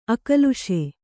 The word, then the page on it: ಅಕ್ಲೇಶ: Kannada